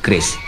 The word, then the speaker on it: QRIS: Keris, Bu. Keris. Q-R-I-S. Dibacanya keris, bukan keris.